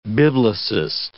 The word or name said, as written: biblicist